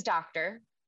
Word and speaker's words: Doctor: Like, I mean, if anybody's seeking permission, my last name is Doctor.